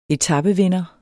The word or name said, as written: etapevinder